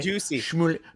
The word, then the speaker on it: Jussie Smollett: Juicy Smollett.